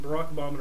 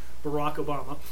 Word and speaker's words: Barack Obama: Mitt Romney and Barack Obama are quite opposite. I said his name wrong. That sucks. Barack Obama. I don't know. I added an N in there.